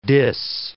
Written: dis

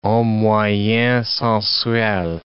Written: homme moyen sensuel

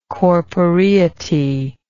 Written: corporeity

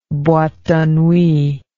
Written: boite de nuit

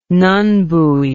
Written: nun buoy

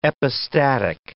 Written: epistatic

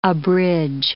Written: abridge

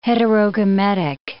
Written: heterogametic

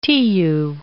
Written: Tiu

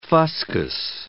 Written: fuscous

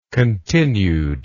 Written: continued

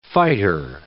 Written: fighter

